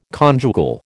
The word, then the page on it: conjugal: American English